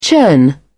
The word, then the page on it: churn: British English